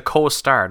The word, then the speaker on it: co-star: I am the coaster. It's because I think you mean you're the co-star, not the coaster.